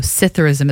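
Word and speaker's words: psithurism: So Citharism, it's spelled really interesting. It has like a P-S-I-T-H-U-R-I-S-M, Citharism.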